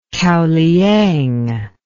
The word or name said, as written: kaoliang